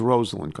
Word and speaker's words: Rosalynn: But what I wanted to point out is that he said Rosalind Carter, not Rosalind Carter. Her name is Rosalind. That's how it's pronounced.